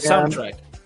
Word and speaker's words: soundtrack: Ah, como, bien, soundtrack.